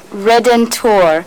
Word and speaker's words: Redentor: Redentor. R-E-D-E-N-T-O-R.